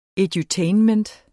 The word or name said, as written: edutainment